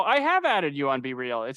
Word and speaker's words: BeReal: BeReal. – I like to pronounce it like it rhymes with cereal.